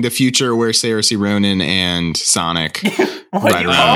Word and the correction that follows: Saoirse: Did you call her the Game of Thrones character Cersei? – No, how do you say her name? – Saoirse Ronan.